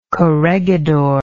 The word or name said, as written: corregidor